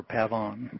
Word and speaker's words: pavon: That was pavon? – Yeah, P-A-V-O-N, or pavon. – Or pavon, all right. – Yeah, depending on how you want to pronounce it.